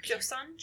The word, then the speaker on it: Joseon: Joseon? – Joseon period.